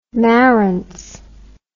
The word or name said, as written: Marantz